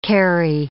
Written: carry

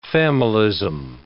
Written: familism

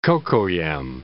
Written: cocoyam

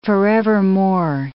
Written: forevermore

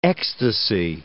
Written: ecstasy

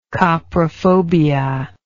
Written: coprophobia

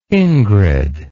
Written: ingrid

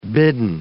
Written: bidden

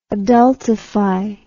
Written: adultify

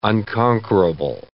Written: unconquerable

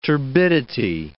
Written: turbidity

